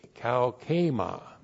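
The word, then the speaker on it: kaukema (καύχημα): K-A-U-C-H, long E-M-A, kaukema.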